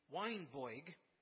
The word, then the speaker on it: Weinberg: Weinberg, who by the way pronounced his name Weinboig,